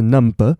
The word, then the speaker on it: numb: We can't get numb to this. – 好了，在这里有一个单字要讲，就是 numb，n u m b，那个 b 是不发音的，不是 number，是 numb。